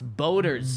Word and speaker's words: BOTR: I like to call us BOTR. Boaters? – Back of the roster. – Back of the roster. Yeah, but he wants to pronounce Boaters.